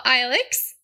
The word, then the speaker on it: Aaluxx: Welcome to the show, Aaluxx.